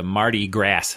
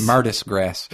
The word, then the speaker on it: Mardi Gras: To see the Mardi Gras thing. – Yeah, I mispronounced it on purpose. – Mardis Gras.